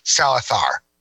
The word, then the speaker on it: Salazar: So, by the way, you mentioned that Javier Bardem plays Salazar in that movie. – Or as he pronounces it in proper Spanish, Salazar.